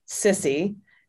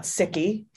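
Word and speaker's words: SSCI: We call the uh, select um, Senate Committee on Intelligence sissy, not sicky.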